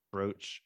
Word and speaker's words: brooch: She's showing showing off a brooch. Am I saying that right? A brooch, brooch. Yeah, sure that her grandma gave her.